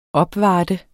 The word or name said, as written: opvarter